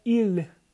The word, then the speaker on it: ils: O eles, em francês, seria il, il. Então você vê que a pronúncia do eles é a mesma pronúncia do ele. Só que se escreve de maneira diferente, se escreve com I-L-S. Il.